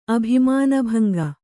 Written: ಅಭಿಮಾನಭಂಗ

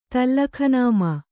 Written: ತಲ್ಲಖನಾಮಾ

